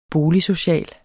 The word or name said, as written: boligsocial